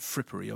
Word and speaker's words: frippery: When I say fippery, I meant frippery, obviously. – Obviously. – Silent R.